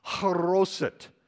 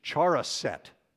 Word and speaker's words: haroset: He dips it into something in front of him that we can identify by just the brief description that we have as something called haroset. If you read it in English, it's charoset, is the way it looks like it's spelled. And it's a mixture of apples and dates, raisins, honey, cinnamon.